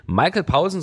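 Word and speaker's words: Michael Poulsen: Sie äh, spricht fließend Dänisch und ich wollte einfach wissen, wie wird der Sänger eigentlich wirklich tatsächlich ausgesprochen? relativ langweilig, ja, Michael Paulsen, aber er selber ist ja total international unterwegs, deswegen Michael Paulsen, so nennt er sich selbst, so nennen wir ihn jetzt auch.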